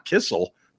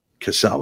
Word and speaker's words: Cassell: I know it's not Kissel. That's his secret agent name. Cassell? Help me out here. – Cassell. – That's what I thought.